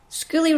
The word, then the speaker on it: Skuli: That's spelled S-K-U-L-I, and Lot is L-G-O-T, but this is how I'm saying it.